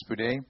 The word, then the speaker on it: spoudai: This word diligence in Greek means spoudai, or is you pronounce it spoudai.